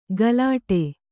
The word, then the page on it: ಗಲಾಟೆ: galāṭe